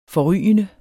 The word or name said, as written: forrygende